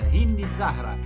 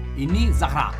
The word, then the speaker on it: Hindi Zahra: cantata da Hindi Zahra, come si pronuncia? Hindi Zahra. – Hindi Zahra.